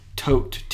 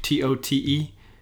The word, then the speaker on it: tote: visiting El Paso, it's taco tote T O T E, but in Spanish it's tote. It just means big taco.